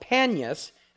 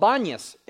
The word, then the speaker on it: Banias: And the reason why it's called Banias is because the Arabs cannot pronounce the letter P. So Panias, from the god Pan or Pan. But because the Arabs can't pronounce the letter P, they say Banias.